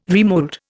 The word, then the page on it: remold: Indian